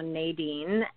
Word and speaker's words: Nadine: You know, people call me both. I typically, though, Nadine.